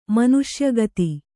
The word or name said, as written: ಮನುಷ್ಯಗತಿ